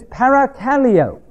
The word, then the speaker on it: parakaleo: The next word is exhortation, which is parakaleo in the Greek. Para, P-A-R-A, means near. Kaleo, K-A-L-E and a long O, means to call near.